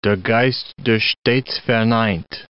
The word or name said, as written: der Geist der stets verneint